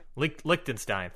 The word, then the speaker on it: Liechtenstein: I don't know if I'm pronouncing that country right. Liechtenstein. Thank you.